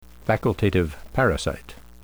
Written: facultative parasite

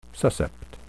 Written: suscept

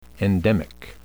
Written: endemic

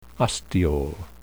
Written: ostiole

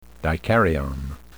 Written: dikaryon

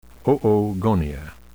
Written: oogonia

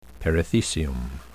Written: perithecium

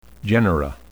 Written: genera

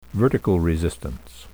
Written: vertical resistance

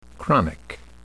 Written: chronic

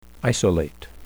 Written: isolate

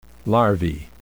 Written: larvae

